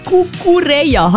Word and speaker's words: Cucurella: Der Cucurella.